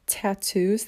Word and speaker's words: tatau: And with their culture, they also have tattoos that they do, but it's spelled T-A-T-A-U, so tattoo, and it's very different than what tattoos really symbolize in a lot of other places.